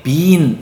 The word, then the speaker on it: been: I've only been 너무 하지 마시고요. been이라고 하시면은 b-e-a-n 콩처럼 되니까요. I've only been to 경주 and Seoul so far 이렇게 been이라고 발음해 주시는 게 훨씬 더 미국인스럽습니다. 아시겠죠? – 예.